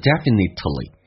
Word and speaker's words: definitely: 那同时这边有一个听力的一个关键点，当 definitely 这个单词我们通常喜欢说成 definitely，那这有个关键就是 t 跟 l 在一起的时候 t 是可以不发音的，就好像 exactly 可以说成 exactly， absolutely 说成 absolutely 一样的道理，所以记住 Definitely, we definitely don't respect, 不去尊敬那些 the people who dish that dirt.